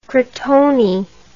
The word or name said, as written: crotone